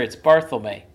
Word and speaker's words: Barthelme: Yeah, no, I'm sure it's Barthelme.